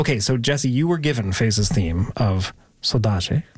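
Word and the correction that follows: saudade: Am I getting better at the pronunciation, by the way? – Sodaji, yeah. – Sodaji. He's not happy. It's Sodaji.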